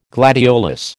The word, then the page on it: gladiolus: American English